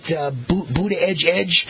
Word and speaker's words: Buttigieg: Pete, I call him Pete Butthead, the mayor of South Bend, but I guess you're supposed to pronounce his name, Pete Buttigieg.